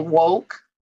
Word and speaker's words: WOKE: Johnson, the founder and president of Women of Color in Fundraising and Philanthropy, aka WOKE with the long O.